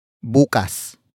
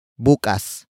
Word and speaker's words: bukas: Koche is spelled as K-O-T-S-E. It means car, the direct object in focus. And finally, we have bukas. Bukas. Bukas. Bukas is spelled as B-U-K-A-S.